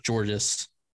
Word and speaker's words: Georges: But it's Georges. – I don't think you have to pronounce the S.